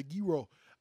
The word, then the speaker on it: gyro: It's almost like the G is silent. Gyro. Like, you only say, the last half of the G. Oh. Instead of guh, it's guh.